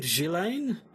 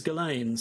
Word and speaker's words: Ghislaine: I'd assumed Ghislaine, but no, everybody says Ghislaine, so let's go with Ghislaine.